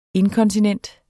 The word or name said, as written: inkontinent